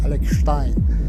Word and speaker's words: Alex Stein: I pronounce it with the German accent, Alex Stein.